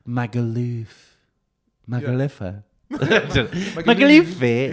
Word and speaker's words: Magaluf: How do you say Magaluf? – Magaluf. – You say Luff. – Magaluf. – As if you're saying Loughborough.